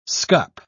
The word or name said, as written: scup